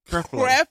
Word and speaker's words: Creflo: Creflo.